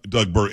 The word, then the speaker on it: Burgum: Burgum kept on jumping, and I like Doug Burgum. It's pronounced Burgum, right? – Yes.